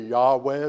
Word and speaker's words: Yahweh: And that's where uh, God, back in, uh, to, to Moses, revealed his name to be Yahweh, is the way we pronounce it.